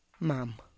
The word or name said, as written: mam